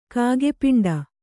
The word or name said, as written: ಕಾಗೆಪಿಂಡ